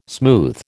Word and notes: smooth: American English

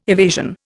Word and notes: evasion: Indian

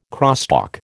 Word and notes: crosstalk: American English

